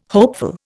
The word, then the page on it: hopeful: Indian